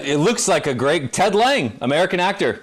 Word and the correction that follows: Lange: Lange. – How about that? – It's pronounced Lange.